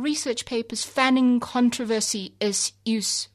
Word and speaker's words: Seus: S-E-U-S. The S is silent. I declare it to be in this poem. Use.